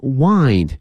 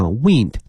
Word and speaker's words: wind: 那么这些架子，the shelves wind up，后面这个词 wind。它这个拼写啊像风一样哈 wind，但是呢它的发音是这个 wind，因为它跟风没有关系了，